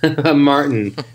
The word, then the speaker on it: Martin: Okay, and I'm Martin. – Martin? – Yeah. Martin, but the T is silent.